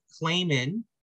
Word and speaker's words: Claman: We are going to be chatting with Alaska State Representative Matt Claman. Did I pronounce that right? – You did. – Yes, sir. So we have Matt Claman.